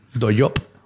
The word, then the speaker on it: Dollop: Se escribe D-O-L-L-O-P como Dollop. – Doyop.